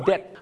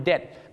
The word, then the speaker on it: debt: ต้องออกเสียงว่า debt I'm in debt ไม่ใช่ debt นะฮะบ้านเราชอบออกเสียง debt ว่า debt